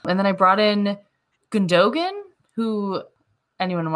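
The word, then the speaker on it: Gündoğan: According to German pronunciations, it's going to be Gundogan.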